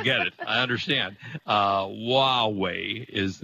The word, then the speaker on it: Huawei: which is Huawei.